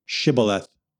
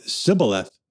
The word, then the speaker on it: Shibboleth: If he said no, they said to him, "Please say Shibboleth." But if he instead said Sibboleth, because he could not pronounce the word correctly, they seized him and slaughtered him at the fords of the Jordan.